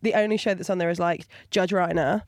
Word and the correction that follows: Rinder: Oh, Judge Rinder. – Rinder, Reiner, Rimmer, whatever. – Thank you. Made it rhyme with grinder there.